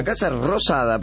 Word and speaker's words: Casa Rosada: Ay, conocí este fin de semana la Casa Rotada. – La Casa Rosada, pronuncie que dijo. Rotada no, Rosada.